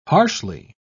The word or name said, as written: harshly